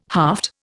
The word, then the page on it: haft: Indian